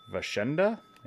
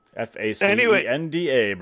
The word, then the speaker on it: Facenda: Vashenda, maybe. – I think it's Vashenda. – Facenda, brother.